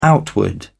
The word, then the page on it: outward: British English